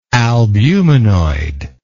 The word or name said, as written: albuminoidal